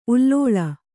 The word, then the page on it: ಉಲ್ಲೋಳ: kannada